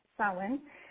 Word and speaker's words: Samhain: And it's definitely pronounced Samhain. Kind of like S-O-W-I-N.